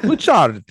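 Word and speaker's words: Bucciarati: Bouchard. You gotta say it like that.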